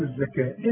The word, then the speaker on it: الزكاة: واتوا، قال الهمزه وسط السطر، واتوا واتوا الزكاه، الزكاه تكتب بالواو وتنطق بالالف. وآتوا